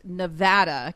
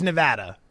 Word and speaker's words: Nevada: They are claiming that all of Nevada or Nevada is going to stop listening to us because in our open, the announcer says Nevada, and they're claiming that it is pronounced Nevada. Can you confirm nor deny you are the one out there in Las Vegas? – Yeah, I live here. It is Nevada.